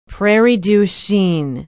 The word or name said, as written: prairie du chien